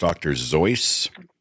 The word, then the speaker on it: Seuss: I think it's actually pronounced Dr. Zeuss. It's a Bavarian name.